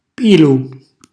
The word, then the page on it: pilu: calabrese